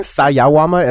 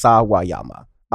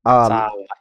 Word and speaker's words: Sawayama: First record of the day, serving up Gary's Way, is Hold the Girl by Rina Sawayama. There we go. – Yeah, so if you say it slower, you'll get it. You're saying Sayawama, it's Sawayama. – Sawayama.